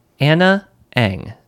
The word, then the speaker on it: Ana Ng: And we're listening to the song Anna Ang. That second word is spelled N-G.